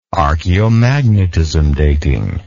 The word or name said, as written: archeomagnetism dating